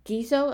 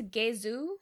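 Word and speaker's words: Ghezo: When the Oyo come to King Gizo, am I pronouncing that right? Or Gezu? – I think it's Gezu. It's kind of Gezu? How they say it.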